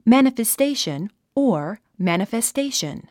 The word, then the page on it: manifestation: mæ`nəfestéiʃən; マナファステイション